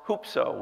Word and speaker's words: hupso: The word lift up that Jesus spoke of here is a Greek word that's pronounced hupso. We would spell it in English H-U-P-S-O.